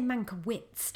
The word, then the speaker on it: Mankiewicz: I should say, I think they say Mankiewicz, don't they, in the film? But I want to say Witz because I've just been taught to have a German pronunciation thing. But I'm going to say how they say it in the film, Mankiewicz.